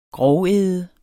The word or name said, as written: grovæde